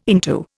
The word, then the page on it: into: Indian